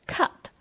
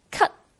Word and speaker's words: cut: 这个英语单词 cut 原来的发音是 cut，而当它夹在这句粤语中使用时，变成了类似粤语咳嗽的" 咳" 的发音 cut。那当然，美式英语有时候口语也会发成这个音。